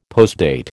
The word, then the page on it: postdate: American English